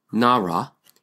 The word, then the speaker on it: Narra: Narra in Tagalog, Filipino, pronunciation Nara is the very same as the Hebrew word Nara.